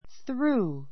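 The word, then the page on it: through: θrúː; するー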